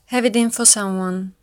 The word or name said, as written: have it in